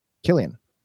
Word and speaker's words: Cillian: How do you say uh, that one? – Killian. – Oh, oh. – Right. – Or if he's a if he's a pothead, it's Chilean.